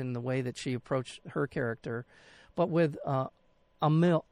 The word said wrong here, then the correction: Amel: Amil, am I saying that correctly? Amil, I mean, uh, I, Amel. – Amel, pardon me.